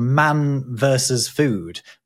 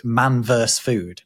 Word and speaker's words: Man Versus Food: There was a TV show that was very popular called Man Versus Food, but in that they always pronounced it Man Verse Food.